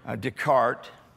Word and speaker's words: Descartes: If you know French, it's just not pronounced. So René Descartes.